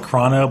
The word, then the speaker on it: Chrono: Okay, I always call it Chrono, but I know it is Chrono because it's like time, you know, like...